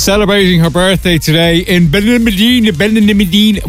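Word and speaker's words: Benalmádena: Over there they say Ben. – Ben Almedina.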